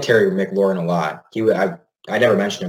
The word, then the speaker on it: McLaurin: McLaurin out in Washington.